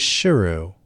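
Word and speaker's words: Sheru: After living as Saru for most of his life, he found out that he had been mispronouncing it. It was Shiru, which means lion.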